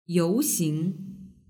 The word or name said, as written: youxing